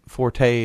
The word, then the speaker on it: forte: forte is wrong. – There's no accent on the E. – Yeah, it's French, so I should have known that. It's pronounced fort.